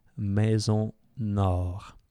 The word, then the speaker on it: Maison Nord: How to spell it? MAISONNORD, Maison Nord. – I know that you guys English people will try to say it. Lewis House, when he says it, is just like Maison Nord, which is quite cool.